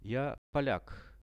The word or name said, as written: ja polak